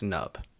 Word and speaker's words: Nub: Or Panub. – I think it's Nub. I think the P is silent.